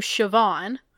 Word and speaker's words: Siobhan: It's like how the name, because the name Siobhan. – Siobhan? – S-I-O-B-H-A-N. – Yeah. I've always said Siobhan, but I should say Siobhan. – Siobhan. – Interesting. – Yeah. B-H pronounced V.